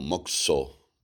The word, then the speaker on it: Mokuso: Mokuso, pronunciado normalmente como Mokso,